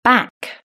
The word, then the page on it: back: British English